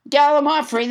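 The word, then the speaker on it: gallimaufry: Let's never forget gallimafry, which gallimafry, which I pronounced wrong until nobody, James Hyder, corrected me. Gallimafry, that's how you say it.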